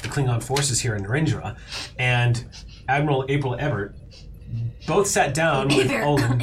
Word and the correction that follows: Hebert: Abear. – Sorry. Oh, Abear. – Rhymes with Hey Bear.